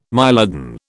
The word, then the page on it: mylodon: British English